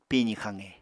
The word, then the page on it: pizi xaqe: Apatani